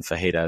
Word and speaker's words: Ferreira: I think I was saying Fajeda. I watch a lot of UFC, as I mentioned before, and Fajeda is how you pronounce that name in Portuguese.